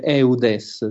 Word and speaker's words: Eudes: che noi diremmo, come si legge, Eudes, San Giovanni Eudes.